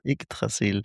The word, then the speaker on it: Yggdrasil: Was ist dieses Yggdrasil jetzt eigentlich? Ich hoffe, ich habe es richtig ausgesprochen. – Ja, total gut. Yggdrasil. Yggdrasil ist die Bezeichnung für den Weltenbaum aus der nordischen Mythologie.